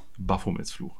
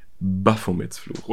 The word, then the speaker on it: Baphomets Fluch: Äh, der, die Betonung liegt auf dem A. Baphomets Fluch. Baphomets Fluch.